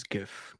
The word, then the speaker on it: GIF: I believe the pronunciation is GIF, KW. – Oh, man. Okay. I don't know, man. I've been team GIF for a long time.